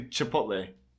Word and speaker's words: Chipotle: And I was like, that's not how you pronounce it. And he's like, No, I'm pretty sure that's how you pronounce it. I was like, no. – No. – No. It's actually Chipotle.